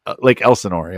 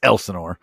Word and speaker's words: Elsinore: Did I keep saying it wrong? Is it Lake Elsinore? – Lake Elsinore, yeah. – I keep saying Lake Elsinore. – Yeah. It's Elsinore. And that might be a regional thing.